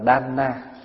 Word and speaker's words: đàn na: Chữ đàn nếu đúng là đọc là đan na, cho nên mình thường gọi các vị Phật tử mà cúng dường đó là đàn na.